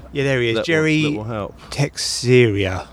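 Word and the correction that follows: Teixeira: Tejera. – Tejera.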